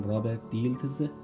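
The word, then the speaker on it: Robert Dilts: رابرت دیلتز. تلفظش هم بگم رابرت دیلتز.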